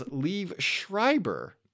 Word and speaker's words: Liev Schreiber: I just wrote down in my notes, Liev Schreiber, because I always get his name wrong. – Yeah, that was wrong. – It is Liev Schreiber, which is difficult.